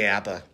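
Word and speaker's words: Apa: KJ Kappa? – KJ Appa.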